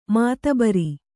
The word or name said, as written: ಮಾತಬರ